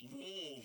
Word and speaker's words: Louisville: And when I said the word Louisville up here in Tennessee, you would have thought, like, it's Louisville. – It's one syllable, Louisville.